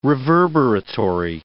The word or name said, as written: reverberatory